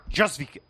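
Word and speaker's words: Jóźwiak: Kamil Hodziak. ¿Hodziak? ¿Hodziak? ¿Se dice así, rata? – Jozvik. – Jozvik, casi, casi.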